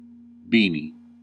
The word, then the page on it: beanie: American English